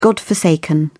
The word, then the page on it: godforsaken: British English